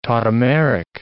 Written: tautomeric

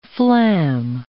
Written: flam

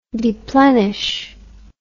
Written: deplenish

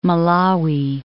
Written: Malawi

